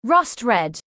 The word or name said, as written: rust red